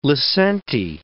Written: lisente

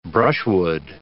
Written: brushwood

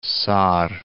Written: Sarre

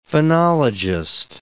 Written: phonologist

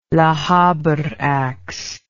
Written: lochaber ax

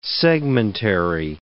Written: segmentary

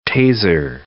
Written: Taser

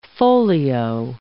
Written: folio

